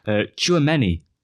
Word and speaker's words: Tchouameni: Tchouameni.